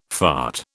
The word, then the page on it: fart: British English